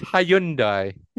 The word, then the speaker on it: Hyundai: What about Hyundai? – Hyundai. – No, it's not Hyundai. – Yes, it is. – It's Hyundai. – No, it's not. The Y is silent.